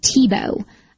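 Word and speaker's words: Thibault: One of our more astute listeners told me that actually pronouncing Thibaut is like Tebow, which my first thought was, huh, that sounds exactly like Tim Tebow, but it's spelled totally different.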